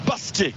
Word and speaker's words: basket: Non, on joue basket. Ah. Et surtout pas prononcer le T.